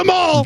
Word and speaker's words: Maul: I think it rhymes with a Maul. – Darth Maul.